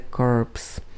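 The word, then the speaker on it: corpse: CORPS, to je ten vojenský útvar, čte se COP, a CORPS, což je ta mrtvola, a čte se CORPS.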